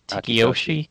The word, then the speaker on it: Akitoshi: Akitoshi,